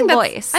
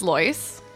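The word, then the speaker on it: Loyce: It's L-O-Y-C-E. I would say Lois. – I think that's Lois. It's like Joyce, but with an L, right? – That's true. – Doria Lois Ragland.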